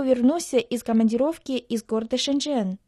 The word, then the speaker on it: 深圳: Название города Шэньчжэн в китайском языке состоит из двух иероглифов. Первый иероглиф «шэн» произносится в первом доне, в переводе на русский «глубокие», «глубоко». Второй иероглиф «чэн» — это четвертый дон, в переводе «ганава».